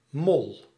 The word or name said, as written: mole